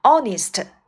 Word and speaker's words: honest: honest 诚实的，注意这个词呢字母 h 不发音，所以一个诚实的人我们可以说成 an honest man。